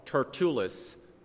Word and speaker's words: Tertullus: And so we're introduced a character called Tertullus.